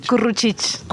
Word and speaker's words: Curruchich: Eh, Nacho, es curruchich. Curruchich. Ah, bueno, curruchich. – Bueno, es que es una palabra en cachiquel que significa pájaro carpintero.